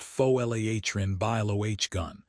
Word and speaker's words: Folarin Balogun: FIFA invoked Article 27 to clear Folarin Balogun, pronounced Fo-L-A-H-rin Bilo-H-gun, for the round of 16 after he was hit with a red card.